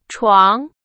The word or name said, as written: 床